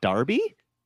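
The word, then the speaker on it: Derby: I'm told it's pronounced Darby.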